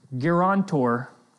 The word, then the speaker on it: guarantor: This makes Jesus the guarantor, how do you say that? You got it.